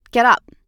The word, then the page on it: get up: American English